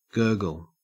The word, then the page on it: gurgle: Australian English